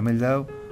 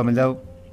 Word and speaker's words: Mehldau: El porqué de traje a Mehldau, según he visto por ahí o he escuchado que se pronuncia. ¿Por qué he traído a Mehldau como cortinas para el día de hoy?